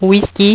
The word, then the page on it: whisky: French